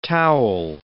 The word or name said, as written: towel